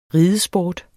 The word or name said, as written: ridesport